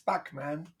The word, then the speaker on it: Bachman: I really enjoy that you pronounce Bachman Bachman. – How do you say it? Bachman. – Bachman. Yeah. – It's back. It's Bachman. Come on. Like just the composer. Back.